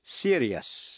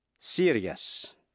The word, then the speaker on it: serious: Còn nếu trước những tiếp vĩ ngữ I-O-U-S không phải là phụ âm C, T hay X, thì đuôi I-O-U-S phát âm thành hai âm tiết, tức là hai syllables, yes. Thí dụ, serious, serious.